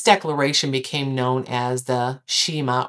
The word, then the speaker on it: Shema: The Shema, or Shumai, depending on how you pronounce it.